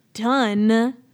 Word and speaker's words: Dunne: Dominique Dune. – Dominique Dunn. – Tip. There you go. It's spelled D-U-N-N-E, okay? – Yeah, that's wrong.